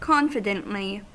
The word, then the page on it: confidently: American English